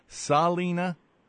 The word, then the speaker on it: Salina: And by the way, just a little personal note, do you pronounce your name Salina? – Yes, Salina. – Okay, I'm pronouncing it like that singer in Las Vegas.